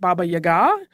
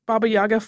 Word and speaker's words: Baba Yaga: I have heard some people pronounce it Baba Yaga. It seems to be a little bit regional in how those play out. Baba Yaga flows a little more naturally for me, so that is what I am going with.